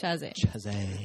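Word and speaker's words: Chasez: J.C. Chazé, did I say it right? Chazé? – Chazé.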